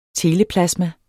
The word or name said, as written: teleplasma